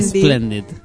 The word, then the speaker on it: Splendid: ¿Cómo se dice esto? Radio Splendid. – Splendid.